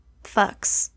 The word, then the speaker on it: Fuchs: The earliest reports were from a Swiss farmer named Hans Fuchs, spelled F-U-C-H-S. I listened to a few things to see how to pronounce it, and I think it is Fuchs.